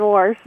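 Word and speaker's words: wash: I've only heard warsh. – Yeah, well, that's just one of the weird things about this warsh pronunciation. It's perfectly natural to a lot of speakers in what we call the Midland dialect, which is a broadly defined area, Pennsylvania, west across Illinois, Indiana, Ohio, down into the Appalachians, and then spreading westward.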